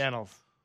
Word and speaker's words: annals: Is it the annals or the annuals? – The annals.